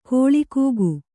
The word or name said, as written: ಕೋಳಕುಕ್ಕಿ